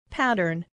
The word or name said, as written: pattern